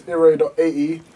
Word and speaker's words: Iray: My Instagram is iray.ae.